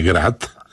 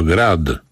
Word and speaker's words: grad: Nie wiem, czy Państwo zauważyli, jak ja wymawiam grad. Nie grad, tylko grad w końcu. Nie mówię gradobicie, tylko gradobicie. Bo ten grad jest twardy. On jest zrobiony z czegoś twardego. Temperatura maksymalna, Zakopane 17, Koszalin 18, Suwałki i Szczecin 19, w Poznaniu, Opolu, Wrocławiu.